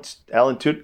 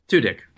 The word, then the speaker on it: Tudyk: But Alan Tudyk, how do you pronounce his name? I'm sorry. – Tudyk. – Tudyk? Okay, yep. Okay, that's what I thought.